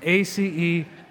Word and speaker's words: acedia: This reality called acedia, well, it's spelled like the word ACE and then think like DIA, Denver International Airport, ACEDIA, acedia.